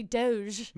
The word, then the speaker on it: Doge: Fancy Doge. – Much value. – I say it with a hard G. Actually, if it was a hard G, it would just be Dogecoin.